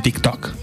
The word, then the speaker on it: TikTok: En TikTok.